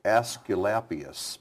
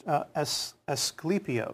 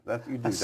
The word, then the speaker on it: Asclepios: It looks like Asclepius, but I know that's wrong. – Oh, it changed. Asclepios. – Oh, see, that's, I like that. Say that again. – Asclepios.